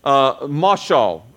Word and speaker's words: mashal: All right, and that word in the original is mashal. Rhymes with mothballs, okay?